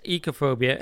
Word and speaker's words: oikophobia: And Thane Scotty of Swindon, oikophobia is pronounced ecophobia and derives originally from the ancient Greek word for the house oikos, and which now means family, oikogenia.